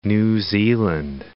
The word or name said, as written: New Zealand